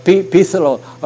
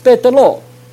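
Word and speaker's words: Berthelot: 在 1860年法国一个科学家他预言，这个人的名字叫 TH，法文的发音不是叫比斯洛而叫贝德洛，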